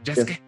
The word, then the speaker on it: Yasuke: se escribe Yasuke, pero se pronuncia Yasuke. Yasuke. – Como Sasuke. Y, como Sasuke, ajá, exacto.